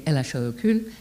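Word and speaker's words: Elle a chaud au cul: Pronounced phonetically Elache au cou, she has a hot arse, as it's translated.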